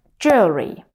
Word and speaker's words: jewelry: Я уверена, что многие пытались произнести его как jewelry или jewelry, но на самом деле оно произносится как jewelry.